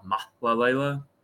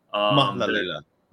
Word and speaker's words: Mahlalela: Is that how you pronounce it? – So technically, yeah, that's like the North. And I saw someone give a tutorial on Twitter and how to say his last name. Technically, his last name, is like Jama Mahlalela. – Mahlalela.